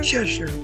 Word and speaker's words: Cheshier: Cheshier. Yep. – Cheshier. Cheshier. This sounds French. Is that French? – It's English. It's like the Cheshire Cat, that kind of deal.